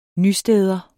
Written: nysteder

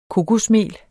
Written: kokosmel

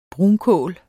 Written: brunkål

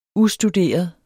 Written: ustuderet

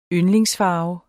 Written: yndlingsfarve

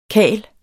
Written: karl

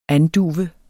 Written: anduve

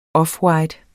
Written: offwhite

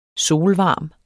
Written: solvarme

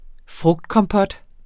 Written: frugtkompot

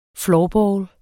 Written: floorball

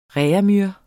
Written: reaumur

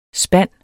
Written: spand

